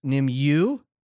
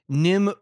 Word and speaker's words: Nimue: He wanted to correct us on our pronunciations of two things, which was Nimue, M-N-I-U-E, which I'm pronouncing incorrect, but he says it's Nimue.